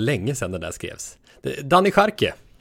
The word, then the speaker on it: Daniel Jarque: Daniel Jarque, kan det vara det? – Ja, det, just det.